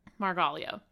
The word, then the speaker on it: Margaglio: Margaglio.